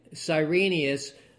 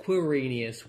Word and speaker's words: Quirinius: Cyrenius, or it's sometimes pronounced Quirinius with a Q,